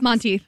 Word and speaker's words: Monteith: This tune was performed by Lea Michele and Corey, how do you say his last Monteith. – Name? Monteith. In the first season finale of Glee, along with several other tunes from this band in that final episode.